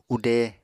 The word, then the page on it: ude: Apatani